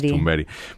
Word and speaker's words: Thunberg: mismo, vamos, estaba diciendo Thunberg o Thunberg y es Thunberg.